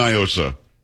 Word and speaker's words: NIOSA: Do not, because it's definitely Niosa. It is not a Spanish word. What does Niosa mean in Spanish? Nothing. – So, I don't know. – Well, that's the whole argument. They try to pronounce it like it's Spanish. It's not. It's an acronym. And for those around the country, it's Night in Old San Antonio. And here we've always said Niosa.